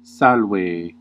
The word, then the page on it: bonjour: /bɔ̃.ʒuʁ/